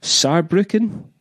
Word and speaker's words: Saarbrücken: And then in Germany, we're playing in Cologne, Hamburg, Berlin, Munich, Saarbrücken. Yeah. Am I saying that right? – Yeah.